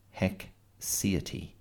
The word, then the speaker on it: haecceity: I'm going to spell it for you. H-A-E-C-E-I-T-Y. Hexiety.